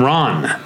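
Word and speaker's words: Ran: Akira Kurosawa's 1985 film, Ron. – That's the correct pronunciation. – Yeah, like Ron Burgundy.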